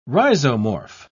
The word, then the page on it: rhizomorph: British English